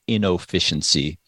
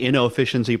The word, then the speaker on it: Innoficiency: I hit the O on it. So I've invented a word, inefficiency. – Inefficiency. – I've combined the words innovation and efficiency into one word.